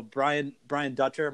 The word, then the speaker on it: Dutcher: So Brian Dutcher, am I saying that right? – Yes.